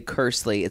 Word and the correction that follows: Kearsley: It's Cody Kearsley.